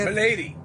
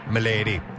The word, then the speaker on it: Maladie: Is that how you say it? – Milady. – M-A-L-A-D-I-E? Milady. Here we go.